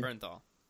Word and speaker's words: Bernthal: Bernthal.